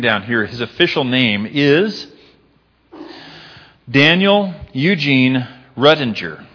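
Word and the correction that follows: Ruettiger: if I'm saying that correctly. What's that? – Rudiger. – Okay, thank you. Rudiger, very good.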